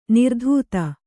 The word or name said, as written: ನಿರ್ಧೌತ